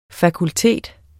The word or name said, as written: fakultet